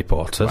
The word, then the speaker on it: Portas: Oh, I like Mary Portas. – Rhymes with short ass.